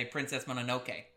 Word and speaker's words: Princess Mononoke: I can make Graham quiver when I say Princess Mononoke. – That was correct. – That was very correct.